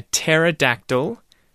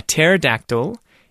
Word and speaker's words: pterodactyl: But the joke there is that the word pterodactyl actually starts with a silent P.